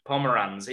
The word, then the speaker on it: Pomeranz: Yeah, have I mispronounced it? The word Pomeranz. It's like, no, you pronounced it fine,